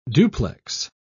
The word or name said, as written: duplex